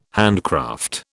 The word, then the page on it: handcraft: British English